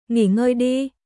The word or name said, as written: Nghỉ ngơi đi